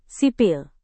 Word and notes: sipil: Indonesian

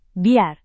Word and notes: biar: indonesian

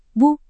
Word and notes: bu: Indonesian

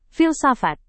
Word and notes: filsafat: indonesian

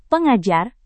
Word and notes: pengajar: Indonesian

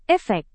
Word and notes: efek: indonesian

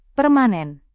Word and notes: permanen: Indonesian

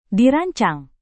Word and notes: dirancang: indonesian

